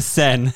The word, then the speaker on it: Seine: It's pronounced Seine.